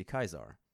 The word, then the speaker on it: Caesar: And then looked it up and it is like in Latin, it's supposed to be Kaisar.